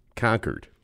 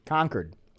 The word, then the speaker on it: Concord: You know, the company is 28 years old, and and now here's the difference: um, the city is pronounced Concord. – Concord. – I learned that. That's right. Whereas we're Concorde, like the big airplane, like you know.